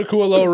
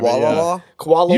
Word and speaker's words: Kualoa: just go to Kualoa Ranch on a different day.